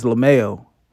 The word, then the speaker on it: LaMail: So my name is LaMail. And most people mess that up because it's spelled like it looks.